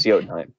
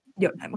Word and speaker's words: Jotunheim: It is Jotunheim. – Jotunheim, okay, good. I thought you pronounced the J at one point, and it's like my brain doesn't know how to do that. So if you're from Jotunheim, why are you in the Axel district?